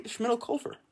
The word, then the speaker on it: Schmidlkofer: Okay, Schmidlkofer.